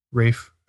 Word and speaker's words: Ralph: You mean Rafe Fiennes? – Rafe. Oh, is it Rafe? – I believe so. – Oh, that's my mistake. – I could be wrong. That's how my wife assures me it's pronounced.